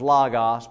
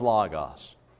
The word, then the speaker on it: Logos: It's an Omicron. An Omicron is pronounced with a short O sound. But it's Logos.